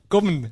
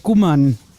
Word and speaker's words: Gommen: Das heißt nämlich Gommen. – Gommen, ah ja, natürlich. Ich wollte es aber, ich habe gesagt, ihr versteht es nicht. – Gommen, nicht Gummern.